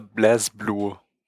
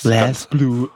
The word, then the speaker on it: BlazBlue: Ja, damit Aldo jetzt äh, nicht äh, alle hier ankackt, äh, BlazBlue heißt es. BlazBlue. Oh, guck mich an, ich kann voll das richtig aussprechen.